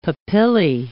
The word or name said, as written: papillae